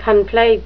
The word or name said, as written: conflate